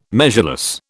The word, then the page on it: measureless: British English